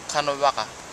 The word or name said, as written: kanawaka